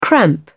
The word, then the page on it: cramp: British English